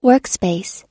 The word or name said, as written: workspace